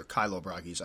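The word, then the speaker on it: Chilobrachys: I have a couple questions I want to answer, and I've been getting a lot of questions specifically about Kilo Brachy species or Kilo Brachys. I say Kilo.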